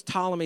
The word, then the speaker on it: Ptolemy: In fact, the Greek governor of Egypt decided, his name was Ptolemy II. It's spelled with a P. It's a silent P. Don't get weirded out by that.